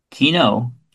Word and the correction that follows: quinoa: quino, Quinoa.